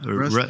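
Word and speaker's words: Roest: How you say it? – Roost. Yeah. Roost. – Roost.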